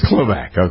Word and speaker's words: Knoblach: A fella named Jim, is it Knoblach? Is that how you pronounce his name? K-N-O-B-L-A-C-H, congressman from Minnesota. – Oh, Klobach, yeah. – Uh-huh. It's Klobach, okay.